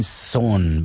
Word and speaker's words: Coh: Und dann, so wie vielleicht auch in Budapest, schob sich hinein Zoh, beziehungsweise vielleicht von einigen anderen auch Zoh ausgesprochen, C-O-H wird es nämlich geschrieben,